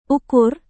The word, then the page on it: ukur: Indonesian